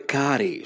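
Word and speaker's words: Kare: Θα πούμε λοιπόν για, την, ε, για μια πολύ ωραία τύπησα τη Σούζαν Κάρε που είναι, ε, ελπίζω να το προφέρω και σωστά το όνομα και να μην είναι ναι, αλλά είναι Κάρε πρέπει, να είναι. Πρέπει να είναι Κάρι, Σούζαν Κάρι.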